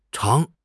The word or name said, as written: chang2